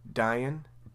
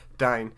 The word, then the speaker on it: Dain: In the real lore, what happens is Azog is killed by, how do you say his name? – Dain. Yeah. – Not dying. Dain.